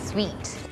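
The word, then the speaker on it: suite: sweet sweet room sweet เนี่ยมันจะออกเสียงเหมือน sweet ที่แปลว่าหวานเลยค่ะมันเป็นคำที่เขาเรียกว่า homophones homophones ก็คือออกเสียงเหมือนกันแหละแต่ว่าคนละคำกันเราก็ต้องสังเกตเอาว่ามันใช้ในสถานการณ์ไหนถ้าเราบอกว่า Can I have a sweet room?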